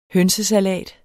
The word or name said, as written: hønsesalat